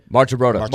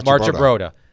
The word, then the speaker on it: Marchibroda: Brian Billick never coached again, and help me pronounce this last name, Ted Marchibroda. – Marchibroda. Neither of them coached after.